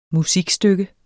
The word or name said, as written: musikstykke